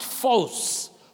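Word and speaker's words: phos (φῶς): That word light is used very often, and it is the Greek word phos, pronounced phos, phos, phos.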